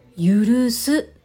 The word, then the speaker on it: 聴す: この字で「許す」と読むそうです